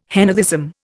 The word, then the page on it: henotheism: Indian